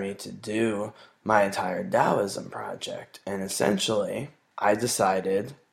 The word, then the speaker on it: Taoism: It is Taoism with a D as in dog. That's how it's pronounced.